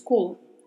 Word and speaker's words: cola: Muita atenção aqui com a pronúncia, porque, como a gente já sabe, a letra O é mais fechada, né? Então falamos cola. Cola.